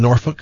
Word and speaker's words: Norfolk: Do you know that it is pronounced Norfolk? – Yes.